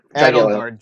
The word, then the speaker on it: Edelgard: No, wait, Edelgard, sorry. – Yeah, Hildegard's the girl from... – Edelgard, sorry, I mispronounced it. Yeah. I said it wrong earlier.